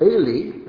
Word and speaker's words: Eli: And what's interesting is that Eli's name, if you were just to hear it, Eli, that's how it's pronounced, sounds like my God.